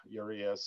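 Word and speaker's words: Urías: And of course, at the Spanish pronunciation of it is Urias.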